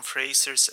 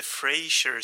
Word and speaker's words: Fraser: Jag har så här ett par veckor efter inspelning konstaterat att man mycket riktigt uttalar Brendan Frasers efternamn just Fraser och inte Frasier som jag tror att jag säger i merparten av det här avsnittet.